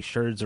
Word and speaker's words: sherds: sherds rhymes with birds.